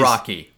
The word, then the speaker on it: Araki: Araki, by the way.